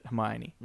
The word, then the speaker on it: Hermione: Rowling was, then you were probably more likely to pronounce it Hermione. – Mm-hmm. – But I think I was Hermoine is what I might have said. Hermoine, okay. Yeah. – Hermoine. – And yeah, like you don't pronounce the E.